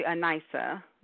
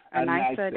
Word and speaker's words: Anissa: Unfortunately, you're not, but it's okay. It's actually Anissa. Long I. Anissa Davis Jackson.